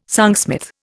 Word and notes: songsmith: Indian

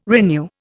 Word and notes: renew: Indian

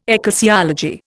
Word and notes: ecclesiology: Indian

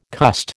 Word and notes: cussed: American English

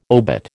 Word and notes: obit: American English

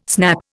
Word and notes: snap: Indian

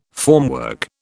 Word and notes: formwork: British English

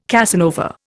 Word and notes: Casanova: Indian

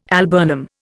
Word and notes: alburnum: Indian

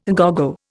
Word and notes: à gogo: Indian

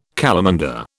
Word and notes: calamander: British English

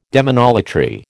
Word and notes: demonolatry: American English

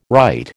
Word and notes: right: American English

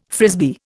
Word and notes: Frisbee: Indian

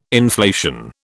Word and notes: inflation: British English

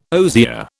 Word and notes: osier: British English

